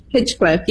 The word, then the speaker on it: Hinchcliffe: Hinchcliffe.